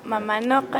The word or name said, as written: Mamanoqa